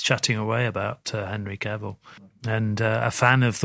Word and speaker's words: Cavill: And I don't know how much training um, Henry is it Carville? Is that how you pronounce him? Cavill.